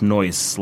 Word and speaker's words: No Ice: So one is with one band I've been playing in, which is called, well, it's written No Ice, but it's pronounced Noise, like a mispronunciation of the word nice.